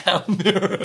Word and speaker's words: Almere: It's good, pronounced Almere. – Good pronunciation, after I corrected him before.